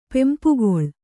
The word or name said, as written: ಪೆಂಪುಗೊಳ್